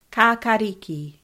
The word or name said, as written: kakariki